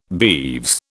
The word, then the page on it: beeves: British English